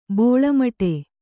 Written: ಬಳ್ಳಿಮಾಡ

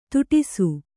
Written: ಥಟ್ಟೈಸು

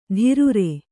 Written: ಧೀರುರೆ

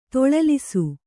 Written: ತೊಳಲಿಸು

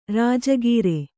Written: ರಾಜಗೀರೆ

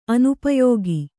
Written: ಅನುಪಯೋಗ

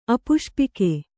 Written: ಅಪುಷ್ಪಿಕೆ

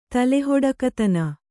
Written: ತಲೆಹೊಡಕತನ

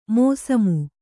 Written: ಮೋಸಮು

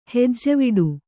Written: ಹೆಜ್ಜವಿಡು

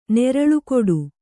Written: ನೆರಳುಕೊಡು

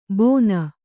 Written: ಬೋನ

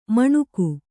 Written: ಮಣುಕು

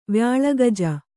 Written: ವ್ಯಾಳಗಜ

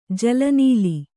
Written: ಜಲನೀಲಿ

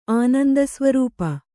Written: ಆನಂದಸ್ವರೂಪ

